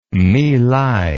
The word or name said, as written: mylai